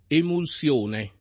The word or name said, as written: emulsione